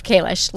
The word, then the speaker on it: Kalish: A lot of people say Kalish. So I'm going to go with Kalish. – This is why I asked you to say it, because I wasn't sure. – Yeah. I believe Kalish, long A.